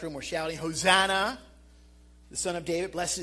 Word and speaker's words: Hosanna: The Greek word for Hosanna, it's pronounced this, Hosanna.